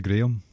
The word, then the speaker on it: Graham: His brother's name is Graham, but it's actually Graham, because we say it properly.